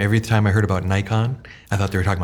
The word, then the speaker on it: Naikan: Um, but this is Naikan, um, which it should be pronounced that way in Japanese.